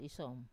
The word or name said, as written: isom